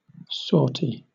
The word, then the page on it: sortie: British English